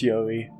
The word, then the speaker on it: Yori: If that is how it's pronounced. – Yeah, it's Yori. Yori, my boy, my good child, is a funny little, is a funny little elvish looking dude.